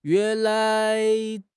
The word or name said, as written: yuan2 lai2